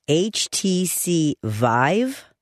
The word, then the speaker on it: HTC Vive: HTC Vive? – Yes.